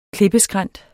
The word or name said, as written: klippeskrænt